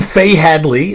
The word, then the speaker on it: Faye Hadley: So you and Faye Hadley, is that, am I saying her name right? – Yeah.